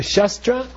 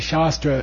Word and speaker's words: shastra: If you say Shastra, it means a different thing. Shastra, I forget which is which. I think Shastra is a sword, and then Shastra is a commentary. You see? So long A, short A in Sanskrit are important.